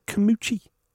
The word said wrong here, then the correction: Carmouche: Carmucci. Is that not how you pronounce her name? – It's Liz Carmouche. – Carmucci. – Just Liz Carmouche. – It's got an apostrophe over the E.